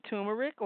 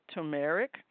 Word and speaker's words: turmeric: So we have the, we have the turmeric or turmeric. And that's spelled T-U-R-M-E-R-I-C, turmeric and other spices.